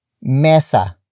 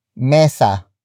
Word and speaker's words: mesa: And that is mesa. mesa